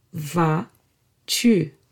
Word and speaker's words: vas-tu: Comment vas-tu? Comment, how, vas-tu, are you? Tu is you. And yes, this va, where the final S is silent, is the same va we've seen in ça va.